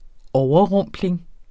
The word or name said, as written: overrumpling